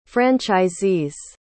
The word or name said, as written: franchisees